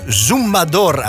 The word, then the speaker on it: Zumbador: Of Zumbador, als ik het goed moet uitspreken. Het is Puerto Rico, hè? Dat is niet het Spaans, het Spanje-Spaans, zeg maar. Dat is altijd net een beetje anders. Dus volgens mij zeggen ze Zumbador, zeggen ze in het liedje.